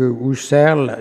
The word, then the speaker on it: Husserl: Reprenons les textes dispersés que Husserl, il n'y a pas de tréma sur le U, donc je prononce OU, a consacré à la question de Dieu.